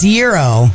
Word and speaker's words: gyro: Is it gyro or gyro? – See, it's gyro is how it's properly pronounced.